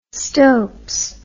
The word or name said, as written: stopes